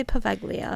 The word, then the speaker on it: Poveglia: I'm going to say Poveglia. – That sounds right to me.